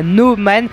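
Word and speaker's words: Nø Man: Alors, je vais le prononcer comme ça, No Man, parce que c'est un O barré.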